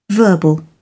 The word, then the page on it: verbal: British English